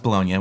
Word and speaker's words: Bologna: The big one was the University of Bologna, which, if you look at it, looks like the word bologna, but it's just delicious, by the way. – But it's pronounced Bologna, which is in Italy.